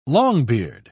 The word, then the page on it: longbeard: British English